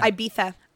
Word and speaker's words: Ibiza: Yeah, but that's how you say it in Spain. España. – España. – Ibiza.